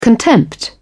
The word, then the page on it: contempt: British English